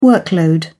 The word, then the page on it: workload: British English